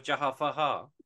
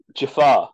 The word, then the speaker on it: Jafar: Return of Jafar. – Jafar. – Yeah, I know. Did you say it wrong on purpose? – Oh, sorry.